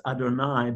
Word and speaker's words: Adonai: We call it Yud-Heh-Vav-Heh, that gets pronounced in our prayers as Adonai,